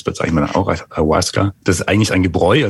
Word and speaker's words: Ayahuasca: Was ist das Ayahuasca und habe ich es richtig ausgesprochen? – Ja, ist richtig ausgesprochen, Ayahuasca.